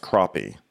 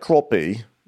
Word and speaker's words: crappie: And so, but it's actually pronounced crappie, like crappie. It's actually pronounced the way Luann would say crappy.